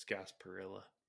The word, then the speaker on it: Gasparilla: Lost to Wake Forest in the Gasparilla Bowl, if I said that right. Is it Gasparilla? – I think Gasparilla. When you're speaking English, it's Gasparilla.